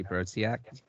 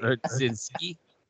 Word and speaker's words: Brodzinski: I wanted to get a Johnny on my team, so I picked Johnny Brodziak. – Hey. – Brodzinski.